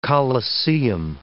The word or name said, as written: colosseum